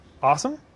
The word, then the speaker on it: OSSM: It spells out OSSM, which pronounced awesome, and it's easy to remember that way.